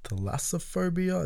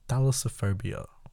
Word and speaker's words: Thalassophobia: Thalassophobia. What did I say? Thalassophobia? Thalassophobia. Okay.